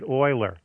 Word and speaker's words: Euler: Well, it's actually named after a famous mathematician who pronounced it Euler.